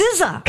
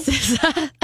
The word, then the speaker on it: SZA: SZA? Or do you say SZA? – I don't know. – SZA, isn't it? – Hope, we look to you for this. – SZA.